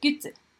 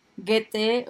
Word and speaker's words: Goethe: Así es, y para empezar este episodio hablaremos primero de las teorías de Goethe o Goethe o bueno, hay gente que lo latiniza y dice Goethe, ¿no? Como prefieran decirle.